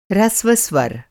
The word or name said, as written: ह्रस्व